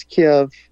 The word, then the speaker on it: Kyiv: Do you pronounce it Kyiv or Kiev when you say that, the name of the capital? – Well, it's Kiev. – Okay. – It's, you know, I watched painfully Fox News pronouncing it Kyiv, and then a Ukrainian on the other end saying Kiev, so.